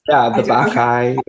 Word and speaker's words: Bacchae: So this is a brand new piece of theater based on, I'm going to try and pronounce this, The Bacchae by Euripides. Did I do it? – Yeah, The Bacchae.